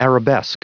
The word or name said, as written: arabesque